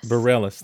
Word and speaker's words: Bareilles: Bareilles.